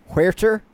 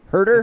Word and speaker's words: Huerter: Huerta, Herder. That's not how you. Yeah, no, it's Herder. Someone came at me on Twitter for saying Huerta.